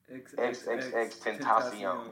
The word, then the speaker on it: XXXTentacion: Can I put out the correct pronunciation? – You're gonna put it out for me. – XxxTentacion. That's his name.